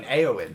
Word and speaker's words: Eowyn: Sorry, Galadriel, Arwen, and Erwin. – Yeah. – Erwin? – She's the Rohan... – You mean Eowyn? – Eowyn, sorry. – Yeah, Eowyn. – Sorry, the mispronunciation.